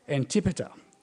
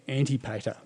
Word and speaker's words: Antipater: The temple is listed by Antipater. I had to stop on that word and look up how to pronounce it, because I want to say Antipater. Maybe that's my Queensland heritage.